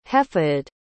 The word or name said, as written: hefeydd